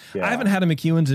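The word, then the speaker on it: McEwan's: How do you spell it? – M-C-E-W-A-N. Apostrophe S, McEwen's.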